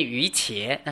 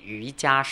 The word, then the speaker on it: 瑜伽: 所以其实瑜伽师地论这个字可以两个发音，一个念瑜伽，那是我们中国的波波摩佛古汉语念伽，但是在唐音是念瑜伽师地论，因为梵文是念 Yogacara，所以两个音都是正确的。